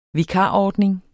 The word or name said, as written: vikarordning